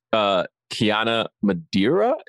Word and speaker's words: Kiana Madeira: It stars Kiana Madeira, is how you say her name, I believe.